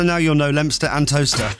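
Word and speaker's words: Towcester: And I take it it's spelt odd as well. Talcester. Yeah, Talcester. – Yeah, but it's toaster.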